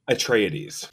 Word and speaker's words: Atreides: Atreides.